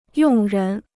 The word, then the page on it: 用人: yòng rén